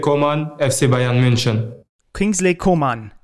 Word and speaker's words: Coman: Also ich bin sehr gespannt, weil, weil es, also man will ihn ja erstmal Coman aussprechen. Aber ich habe mir mal erklären lassen, dass es in seinem Fall Coman so, ist.